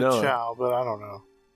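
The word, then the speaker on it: Chao: And then I realized, I'm like, no, you're saying Chow, as I've been made to understand it, but, you know, cow.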